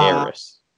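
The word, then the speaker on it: Heiress: Harris. Sorry, no H. H is silent.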